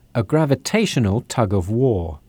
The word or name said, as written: tug of war